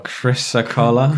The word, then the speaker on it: chrysocolla: Oh, chrysocolla.